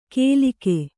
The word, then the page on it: ಕೇಲಿಕೆ: kēlike; Kannada